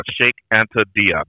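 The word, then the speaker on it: Cheikh Anta Diop: Shake Anta Diop, D-I-O-P.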